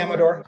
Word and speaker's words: Amador: Am I pronouncing that right? Amador. – Amador, yeah. Mm-hmm.